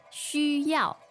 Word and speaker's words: 需要: 需要吗? – So this next word is the verb, right? – Yes. 需要. X-U, first tone. Y-A-O, fourth tone. 需要.